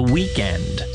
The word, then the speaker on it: Weeknd: I'm not sure how to pronounce his last name, although I can pronounce his stage name, which is The Weeknd, spelled W-E-E-K-N-D.